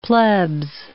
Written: plebs

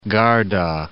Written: Garda, Lake

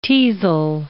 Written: teasel

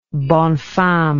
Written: bonne femme

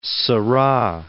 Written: Seurat

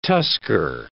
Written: tusker

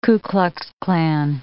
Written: Ku Klux Klan

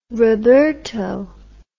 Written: roberto

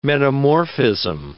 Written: metamorphism

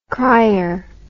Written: cryer

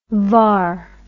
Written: var

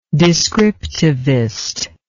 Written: descriptivism

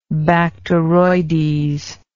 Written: bacteroides

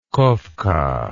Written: Koffka

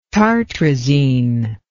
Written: tartrazine

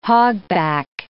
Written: hogback

